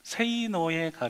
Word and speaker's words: 세이노: 그러니까 세이노가 원래 발음은 이렇게 하면 안 되고 세이노, 그러니까 영어의 say 그리고 no.